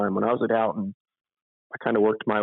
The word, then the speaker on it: Houghton: So you spent 17 years at Houghton. Am I saying that right? – Yes, you are.